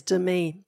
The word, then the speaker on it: Demi: It's Demi. Don't forget, you put the emphasis on the wrong syllable.